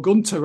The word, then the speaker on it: Gunther: I think the thing that really incom- impeded them was the knowledge that Gunther or Gunter rather, I'll pronounce it correctly at some point, he's not going to lose that title until after mid September, after he breaks Honky's record.